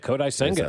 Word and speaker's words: Kodai Senga: Kodai Senga? – Yeah, Kodai Senga.